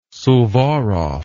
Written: Suvorov

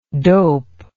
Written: doped

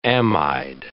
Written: amide

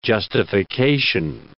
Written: justification

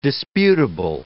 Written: disputable

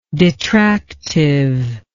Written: detractiveness

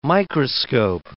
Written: microscope

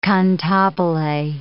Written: cantabile

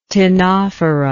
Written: ctenophora

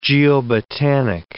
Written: geobotanic

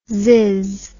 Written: zizz

